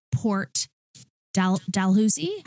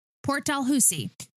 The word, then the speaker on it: Port Dalhousie: And they moved out of the Homolka house to a rented Port Dalhousie. How do we say that, Danny? – Yeah, that's it. – Get out. – Yeah. – Oh my God. First try. SOS. Port Dalhousie.